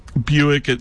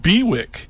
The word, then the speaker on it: Bewick: They're just a cute little bird with a big voice, a lot of personality, and Buick, it looks like it's spelled Bewick, but it's pronounced Buick like the car, Ford Chevy Buick, but it's spelled B-E-W-I-C-K.